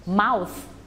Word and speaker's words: mouth: และเสียงลงท้ายด้วยเสียง th mouth